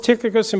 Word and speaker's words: Tychicus: Although there was a couple of variations online of how you say it, but Tychicus is the most common one.